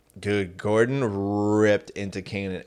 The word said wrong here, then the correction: Kynan: And it is weird. – Kynan. – I mean, it's Kynan.